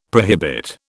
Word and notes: prohibit: British English

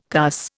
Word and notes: cuss: Indian

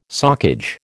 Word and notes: socage: American English

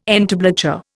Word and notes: entablature: Indian

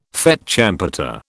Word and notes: fête champêtre: British English

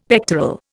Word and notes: pectoral: Indian

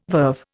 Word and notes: verve: Indian

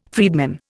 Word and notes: freedman: Indian

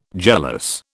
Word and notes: jealous: British English

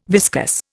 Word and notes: viscus: Indian

